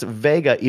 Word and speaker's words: Wega: And it's pronounced Vega, even though it's spelled Wega.